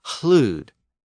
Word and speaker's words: hlud: Loud was hlud, H-L-U-D, and lord was hlavard, H-L-A-F-O-R-D.